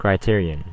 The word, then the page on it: criterion: [kɹaɪ.ˈtɪɹ.i.ən]; /kɹaɪˈtɪəɹiən/; /kɹɪˈtɪəɹiən/; /kɹaɪ.ˈtɪə.ɹɪən/